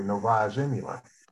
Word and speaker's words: Novaya Zemlya: Novaya Zemlya.